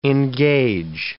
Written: engage

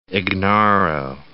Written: Ignarro

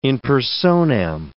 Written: in personam

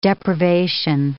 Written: deprivation

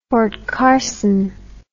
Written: Fort Carson

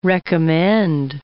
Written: recommend

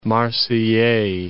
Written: Marseillais

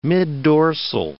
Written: middorsal

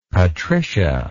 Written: Patricia

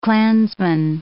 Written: clansman

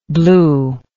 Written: bluely

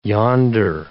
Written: yonder

